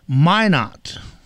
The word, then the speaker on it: Minot: Minot.